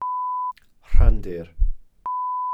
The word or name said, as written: rhandir